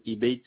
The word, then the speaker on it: Ibayt: Et monsieur Jacob Ibeit Ahmed. – Ça s'écrit comment le nom de famille, monsieur? – Ça, c'est Jacob comme le Jacob prénom. – Oui. – Jacob, et un peu loin, c'est Ibeit, ça s'écrit IBAYT, D de Daniel.